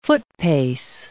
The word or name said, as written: footpace